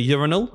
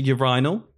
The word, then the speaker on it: urinal: Oh, I also had someone complain to me about, I called it a urinal. Apparently it's a urinal. – Yeah, urinal.